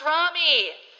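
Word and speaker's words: Ramy: It's pronounced Rami.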